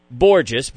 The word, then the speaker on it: Borges: E-V-E-R-L-A-Y-N. Borges, B-O-R-G-E-S, rhymes with gorgeous.